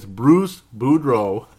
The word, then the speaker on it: Bruce Boudreau: It's Bruce Boudreau. it's not that hard to pronounce. It's just that they're similar and you can screw it up really easy.